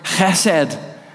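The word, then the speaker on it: chesed (חסד): It's the Hebrew word chesed. Chesed. It's spelled H-E-S-E-D. Some people start it with a C.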